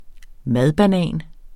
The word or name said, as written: madbanan